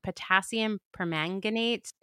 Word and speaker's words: potassium permanganate: I'm hopefully going to be able to pronounce this correctly, but it's potassium permanganate. – Oh, you did a great job. Yep.